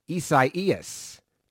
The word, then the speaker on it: Isaias: They put out a video of properly how to say this name? – Correct. – All right. So the way I say it, Isaias, Tropical yep.